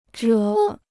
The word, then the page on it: 者: zhě